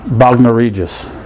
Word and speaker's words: Bognor Regis: we did a Genesis Factor in Bognor Regis.